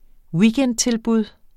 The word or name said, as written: weekendtilbud